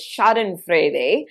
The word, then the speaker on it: Schadenfreude: Now, there is a German word for this, and if I pronounce it correctly, it's Schadenfreude.